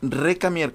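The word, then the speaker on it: Récamier: Ella es Madame Recamier es recamier con acento en la E, ¿verdad? – Se pone el acento por ortografía francesa, en realidad es recamier. – Recamier. – Recamier. ¿Y cómo, a ver cómo lo pronunciarías en francés? – En francés es recamier. – Oye, pronuncias bien francés. – El acento es para no decir la R al final.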